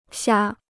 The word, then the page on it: 瞎: xiā